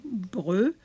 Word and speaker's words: Breut: Et après, c'est François Breut, B-R-E-U-T, ça se prononce Breut ou Breut, à Pic.